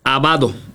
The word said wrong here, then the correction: Abdo: ¿es correcto? – Bueno, José Abdo. – Abdo, con la B, Abdo.